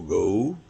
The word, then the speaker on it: goal: To go, e aí a pronúncia é muito parecida, mas ela vem do inglês G-O-A-L, lendo o que está escrito goal, né? Mas que em inglês se diz go, que quer dizer alvo, né?